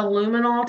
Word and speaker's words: luminol: There was never a luminol test, did I say that right? – Yes, mm-hmm. You did.